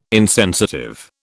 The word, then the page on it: insensitive: British English